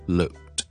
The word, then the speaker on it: looked: 咁先讲咗先，look 咧加 ed 咧，其实后面系加个 t 音嘅。咁我听落去咧系 looked looked looked，系一个先 k 后 t 嘅发音。